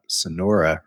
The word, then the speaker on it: Sanora: I'm kind of curious to know, before we talk about Sonora herself, whose name is spelled S-A-N-O-R-A, not like the Sonora Desert,